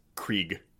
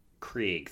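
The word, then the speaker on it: Krige: It's Alice Krieg. – Krieg, thank you.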